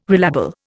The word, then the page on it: relabel: Indian